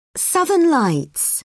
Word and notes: southern lights: British English

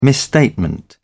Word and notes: misstatement: British English

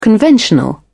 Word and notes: conventional: British English

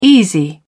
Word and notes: easy: British English

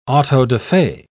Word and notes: auto-da-fe: British English

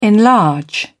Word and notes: enlarge: British English

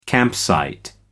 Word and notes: campsite: American English

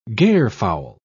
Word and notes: garefowl: British English

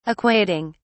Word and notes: equating: British English

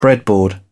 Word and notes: breadboard: British English

